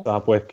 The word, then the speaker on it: Sopwith: Sopwith Camel. – Yeah, it's spelled S-O-P-W-I-T-H.